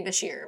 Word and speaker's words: Beshear: So I just want to shout out to the governor, Andy Beshear. I hope I pronounced that right. – I think it's Andy Beshear, but all right. Close enough.